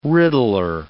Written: riddler

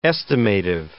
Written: estimative

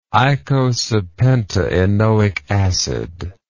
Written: eicosapentaenoic acid

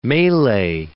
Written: melee

